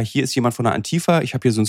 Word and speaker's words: Antifa: Antifa. So, sie sprechen das so deutsch aus. Also eigentlich müsste es ja Antifa ausgesprochen werden, aber egal.